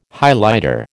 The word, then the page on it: Highlighter: American English